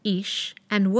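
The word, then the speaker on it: Isha (אִשָּׁה): In the Genesis narrative, chapter 2, verses 20 to 25, not only do man, Ish, and woman, Isha, phonetically sound like a pair, but Isha also means her man in Hebrew, because out of her man, this one was taken.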